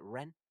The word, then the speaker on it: Rennes: Is it Rennes? – You do run. You do run. Yeah, I believe it's Rennes. – Rennes. – French will always correct you. This is just something I've noticed over the years. You think you've got the perfect pronunciation and the French will go, no, it's Rennes.